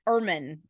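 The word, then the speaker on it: Germán: Domingo Erman, right? Because you don't pronounce the G.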